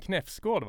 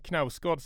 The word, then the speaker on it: Knausgård: om eh, hur uttalar man Knäfsgård va? Säger vissa besserwissrar. – Jag tror det är en, eh, jag tror man säger det på norska. – Ja. Men, men Knausgård säger vi då.